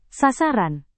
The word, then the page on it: sasaran: indonesian